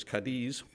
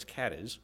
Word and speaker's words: Cadiz: If you were a Spanish speaker, you might be tempted to pronounce it as Cadiz, but in Ohio, it's Cadiz.